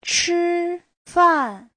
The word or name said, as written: chi1fan4